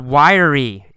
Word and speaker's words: wiry: Next paragraph, we see the word wiry. It's spelled W-I-R-Y. But in fact, we say it with three syllables.